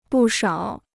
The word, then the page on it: 不少: bù shǎo